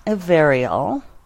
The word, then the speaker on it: Averial: um, so, yeah, she takes the name Averial, and I guess I say it, well, you can hear me saying it. Um, yeah, with just a little, it's you don't even pronounce the I all that much.